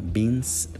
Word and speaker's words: Binns: un blog que se llama Beans, porque ese es mi apellido, no es beans de frijoles, es beans que se escribe B-I-N-N-S.